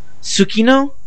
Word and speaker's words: Tsukino: First thing I want to explain to you is the sound that's spelled T-S-U. It is pronounced different ways depending on where in the word it is. If it's at the beginning of the word, the T is usually silent. But if it's in some other part of the word, then you usually pronounce the T. But it's usually attached to whatever it is before it. So, for example, Sailor Moon, her last name is Tsukino.